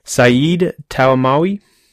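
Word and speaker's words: Said Taghmaoui: Saeed Talmawi.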